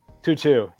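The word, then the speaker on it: To'oTo'o: It's T-O-O-T-O-O-O. – Oh, Tutu? – Tutu, yeah.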